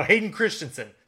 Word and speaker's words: Hayden Christensen: He did kind of remind me of a young Christian Haydenson. I might have totally butchered that. Oh, Hayden Christensen.